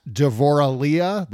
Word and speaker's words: Devorah Leah: You can also find Donna on Twitter at Devorah Leah. That is, am I pronouncing that correctly? – You are. That's my Hebrew name. Devorah LEAH. That's yes. – My, like, Devorah is Deborah, Leah is Lee.